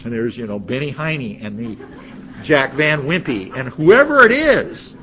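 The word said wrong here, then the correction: Van Impe: Did I mispronounce their names? Jack Van Impey, sorry.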